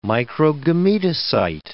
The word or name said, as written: microgametocyte